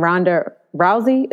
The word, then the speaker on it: Ronda Rousey: Ronda Rousey. Is that how you pronounce it? – Yes.